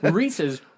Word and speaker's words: Reese's: So Reese's rhymes with pieces.